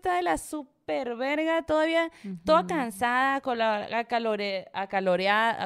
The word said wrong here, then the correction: acalorada: Ah, ¿Cómo se dice? Acalorada. Acalorada, ajá. Em, y luego todavía, no, o sea, me hizo como que no puedo creer que seamos capaces de todo esto.